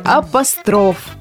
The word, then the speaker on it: апостроф: К слову сказать, вспоминая французов, можно заодно и вспомнить, что во французском языке ударение падает на последний слог, так же, как и в слове апостроф.